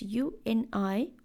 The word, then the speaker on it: Uniyal: How do you pronounce Uber? And they're like, oh, Uber. I'm like, exactly. Now you use that sound and you pronounce Uniyal.